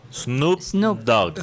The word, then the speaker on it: Snoop Dogg: El cantante, ¿cómo que se pronuncia? Snotdog. Snoop, Dogg.